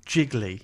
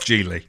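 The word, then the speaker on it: Gigli: Jiggly. – Geely. – Geely. Well, oh, we've all got something Giggly. – Here. – Well, you're both wrong. It's Geely.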